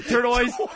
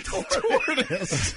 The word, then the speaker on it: tortoise: How do you say it? Turtoise? – Tortoise. You're an idiot.